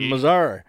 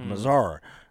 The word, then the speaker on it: Missouri: So in Mazar. And so we get down there, got to pronounce it right, with some, you know, put some respect on it with Mazar. – Mazar.